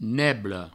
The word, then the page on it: nebla: neblo